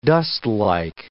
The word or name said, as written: dustlike